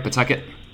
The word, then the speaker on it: Pawtucket: Yeah, did I say it right, Pawtucket? – That's right, yeah. You dropped the A and W, and you'll speak like a true Rhode Islander.